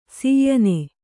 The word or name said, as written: ಶಯನ